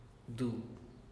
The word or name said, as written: du